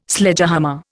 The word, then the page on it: Sledgehammer: Indian